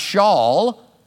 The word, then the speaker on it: Shaul: In Hebrew, it would be pronounced Shaul.